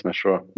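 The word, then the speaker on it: Mashrou': Did I say that right? Mashru? – Yes, Mashrua.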